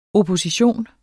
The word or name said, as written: opposition